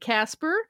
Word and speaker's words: Kacper: And it is spelled the Polish way, K-A-C-P-E-R. Our listener wrote in and said, I would like to nominate Casper for Internet Birdie of the Week.